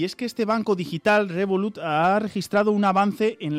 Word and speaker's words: Revolut: Profesor, seguimos, encontramos en El Economista que, no sé si lo digo bien, Revolut logra casi el 20% de las nuevas altas de clientes de banca. Adelante, ¿tú cómo lo dirías? ¿Cómo lo pronuncias? – Revolut.